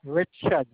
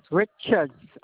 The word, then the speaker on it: Richards: And that last name is R-I-C-H-A-R-D? – Yes. Richards.